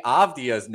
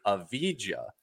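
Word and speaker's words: Avdija: You pronounced Denny Avdia's name as Denny Avdia. – Yeah, I've been doing that for 3 years. Yep. – Yeah. It is Avdia.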